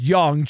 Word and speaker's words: Jung: It looks like it should be Jung, but it's pronounced Young.